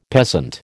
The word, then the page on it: peasant: American English